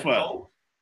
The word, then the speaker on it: pho: Vietnamese is a soup called pho, P-H-O. Vietnamese soup. – Oh, yes, I have pho. You said pho. – Yeah, well, it's pho. It's pronounced pho, even though it's spelled P-H-O.